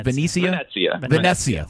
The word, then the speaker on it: Venezia: Venezia.